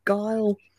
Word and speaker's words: Giehl: Number 38, Brett Giehl. G-I-E-H-L. Guile.